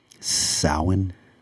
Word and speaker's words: Samhain: Samhain. That's how you pronounce it in Gaelic. Oh, Irish Gaelic and Samhain is actually the end of the harvest season and the beginning of winter.